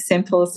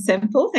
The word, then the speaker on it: Sempill: My name is actually pronounced Sempill, so it's Sam Sempill.